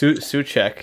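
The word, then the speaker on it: Souček: Thomas Suchek. He's so good. Did I say that wrong? – No. It's right. That's right.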